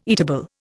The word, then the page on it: eatable: Indian